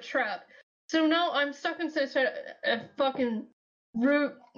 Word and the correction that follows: Rube Goldberg: machine? I can't pronounce it right now. – Rube Goldberg. – Yeah, thank you.